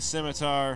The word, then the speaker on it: scimitar: He's going to try to swing at you with his scimitar.